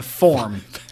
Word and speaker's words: form: I feel like I snuck an extra syllable in there. Forum. It's a form. You fill out the form.